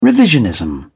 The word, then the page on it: revisionism: British English